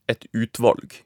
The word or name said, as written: et utvalg